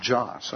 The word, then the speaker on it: Jos: In Nigeria, specifically in the city of Jos, I believe is the way you pronounce it, J-O-S, there have been a number of people who have been beaten and killed.